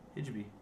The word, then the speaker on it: hijabi: I saw like a hijab girl. It was like a meme. Hijabi. How do you say it? Hijab? Hijabi? – Hijabi.